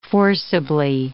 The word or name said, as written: forcibly